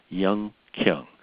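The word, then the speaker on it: Hyun-Kyung: Her first name is hyphenated, H-Y-U-N hyphen K-Y-U-N-G, and it's just pronounced Young Kyung.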